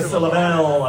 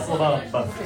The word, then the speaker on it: syllabub: Not a syllable, a syllabub.